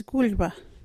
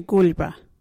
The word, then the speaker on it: gulyba: Our local Wadjuri word is gulba. What is a gulba? Gulba is clothes. You can use gulba in this sentence. Where is your gulba? Gulba is spelt G-U-L-Y-B-A.